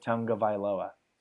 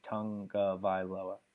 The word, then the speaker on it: Tagovailoa: That's how you pronounce his name? – Tagovailoa? – Tagovailoa. Okay. There's no like N or there's no N in it, but there was like a video where someone asked him how to pronounce it, and he said Tagovailoa.